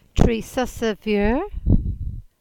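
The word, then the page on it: Tresussa Veur: Cornish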